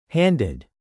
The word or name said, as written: handed